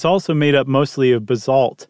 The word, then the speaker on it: basalt: Which I think in the history of Stuff You Should Know, the 13-year history, it's the first time I said it correctly right off the bat. – Instead of what? What did we used to say? – Basalt or basil, basalt.